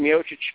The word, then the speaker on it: Miocic: Stipe Miocic, or they now are, you know, saying it more along the lines of the way it should be pronounced in Miocic.